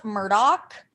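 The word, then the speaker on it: Murdaugh: Yeah, it's actually pronounced Murdoch.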